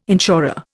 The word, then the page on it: insurer: Indian